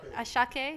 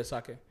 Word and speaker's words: Asake: How do you know how to say his name? Probably Asake or... – Ashake. – Is that how you say it? – Yeah. – I always said Asake.